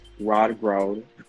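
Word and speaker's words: rødgrød: The eighth fact is red grout, which is pronounced rod grout.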